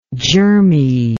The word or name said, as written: germiness